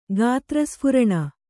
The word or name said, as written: ಗಾತ್ರಸ್ಫುರಣ